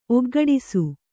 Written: ಒಗ್ಗೂಡಿಸು

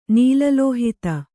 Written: ನೀಲಲೋಹಿತ

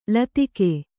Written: ಲತಿಕೆ

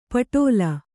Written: ಪಟ್ಟಲು

